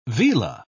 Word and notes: vela: British English

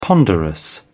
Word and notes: ponderous: British English

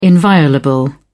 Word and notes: inviolable: British English

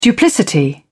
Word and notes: duplicity: British English

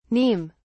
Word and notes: neem: British English

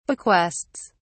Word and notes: bequests: British English

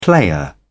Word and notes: player: British English

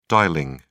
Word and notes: dialling: British English

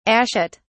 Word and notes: ashet: British English

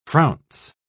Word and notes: frounce: British English

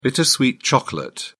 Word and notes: bittersweet chocolate: British English

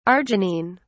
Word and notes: arginine: British English

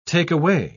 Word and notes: take-away: British English

